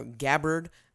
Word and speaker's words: Gabbard: Then it goes Miss Tulsi Gabbard, uh, Gabbard. I'm so told that is her the correct pronunciation.